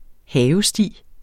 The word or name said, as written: havesti